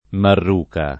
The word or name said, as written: marruca